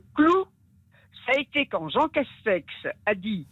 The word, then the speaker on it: Castex: Oui, presque, mais Jean Castex, oui. J'avais il y avait une petite prononciation qui m'avait amusé, mais vous l'avez bien dit.